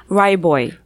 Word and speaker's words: Ryaboy: Well, kind of. – Correct me, please. – Ryboy. – Ryboy. So the vowel in the middle just does not exist.